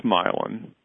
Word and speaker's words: myelin: Do you think that the I don't know if I'm going to pronounce this right, myelin sheaths, the material. – Myelin. – That myelin, thank you.